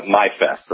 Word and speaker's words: MI Fest: Yeah, and actually, it's although the event is spelled MI Fest, it's my fest. Like, you know, my fest, your fest.